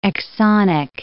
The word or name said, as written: exonic